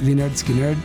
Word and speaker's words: Lynyrd Skynyrd: Y ahora nos dimos cuenta de que, claro, su primer disco de 1973 se llama Pronounce Lynyrd Skynyrd. Entonces, ellos mismos nos estaban diciendo y no nos acordábamos que, claro, el primer álbum así se llama. Para los que dudaban, ahí está. El título del álbum es como pronunciar el nombre de la banda.